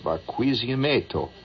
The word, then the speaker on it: Barquisimeto: I would say it's pronounced Barquisimeto.